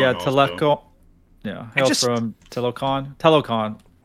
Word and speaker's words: Talokan: Talokan? Is that how you pronounce their land? – Talokan? – I think you said it right.